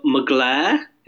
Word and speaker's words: Mugler: She did a yellow mugler. Is that how you say it? – Yeah.